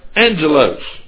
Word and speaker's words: angelos: So in the Greek text, in the New Testament, the same word is pronounced angelos, and which, like the Hebrew definition, means messenger.